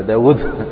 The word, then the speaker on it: داود: وعمر يعتبر ثلاثيا باعتبار النطق به، يعني لأن الواو المكتوبة فيه زائدة خطا لكنها غير منطوقة، يعني يقولون في الإملاء يعني يقولون سرق عمرو الواو من داود، يا داود داود في النطق هي بواوين بس تكتب واو واحدة، فأين الواو الثانية؟ أخذها عمرو فأضيفت.